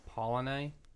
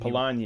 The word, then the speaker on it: Polanyi: i learned about this guy named michael polanyi i forget Sorry. how to say his last name um, but Polanyi.